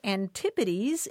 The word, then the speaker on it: antipodes: The word spelled A-N-T-I-P-O-D-S, he wrote, isn't pronounced antipodes. It's pronounced antipodes. And you know what? He's exactly right. The Oxford English Dictionary defines antipodes as,